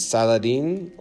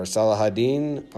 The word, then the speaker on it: Saladin: It's a picture of a guy named Saladin or Salahadin or Saladin, depends on your pronunciation.